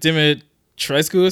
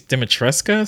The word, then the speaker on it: Dimitrescu: I think it's like you would think it's like Dimitrescu or something like that, but it's apparently pronounced like I think it's Dimitreska or something like that. Like, it's a really weird, like, the last syllable or two was like really weird.